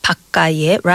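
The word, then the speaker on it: 밭가에: I can see our script and it says 바까에, but we actually have to pronounce it as 바까에. – 바까에, right? And we don't really pronounce 바. – Okay. – We pronounce just 바까에.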